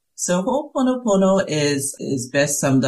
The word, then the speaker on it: Ho'oponopono: and I may not be saying it right, but Ho'oponopono therapy. Am I saying that close to right? – It's Ho'oponopono.